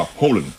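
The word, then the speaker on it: Haaland: นะครับคนนอร์เวย์ต้องเสียงว่าโฮลัน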